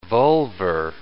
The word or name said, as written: vulvar